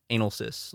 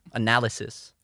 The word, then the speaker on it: analysis: I knew a kid in high school that would only say anal cis. Like, or. – Actually? – Yeah, like fully seriously. He's like, that's how you say it.